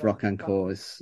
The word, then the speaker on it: Rocquencourt: And just to say that the pronunciation of Rocquencourt is spot on, particularly in that English way. – We're not good like that, but the key is for everybody out there's no T on the end, right? – On le prononce pas, effectivement. – No, not pronounced the T at the end.